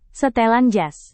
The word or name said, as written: setelan jas